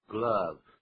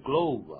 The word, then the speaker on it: glove: Except for 除了什么东西呢？One bloody 沾满血迹的啊，血迹的 glove，这个不要念成 glove 啊，手套怎么念呢？Glove， glove。